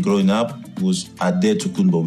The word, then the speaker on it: Adetokunbo: So in the D, they put NT, mm-hmm. and in the B, they put MP. – Ah, okay. – So it's pronounced Adetokunbo in the Greek way.